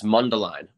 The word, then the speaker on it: Mundelein: So, in, is it, sorry, is it Medellin or Medellin? How do I pronounce that again? – It's Mundelein. Mundelein.